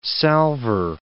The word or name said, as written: salver